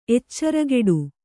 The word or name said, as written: ಎಚ್ಚರಗೇಡಿ